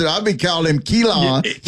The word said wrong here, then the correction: Keelon: But it's Keelon.